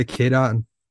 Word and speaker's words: Cade Otton: Yeah, Cade Otton.